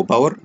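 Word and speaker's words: Bower: بعد كده عندي الديبندنسي، هلاقي عندي اللي انا عندي الباكج بتاع جافا سكريبت وسي اس اس المشهور اسمه بور او باور، في ناس بتنطقها كده وفي ناس بتنطقها كده.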